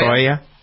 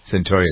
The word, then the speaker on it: Cyntoia: Is that how you pronounce her name? Cyntoia? – Cyntoia. – Cyntoia. – Cyntoia, yeah.